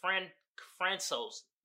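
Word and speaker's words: François: It's not pronounced Francis. It's like Francos Uzan.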